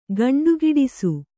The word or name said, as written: ಗಂಡುಗಿಡಿಸು